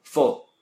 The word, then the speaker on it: fall: En presente es con A. Y se pronuncia como O por alguna razón. – Fall. – Ajá. ¿Cómo se escribe? – Fall, se escribe F-A-L-L.